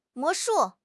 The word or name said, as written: 魔术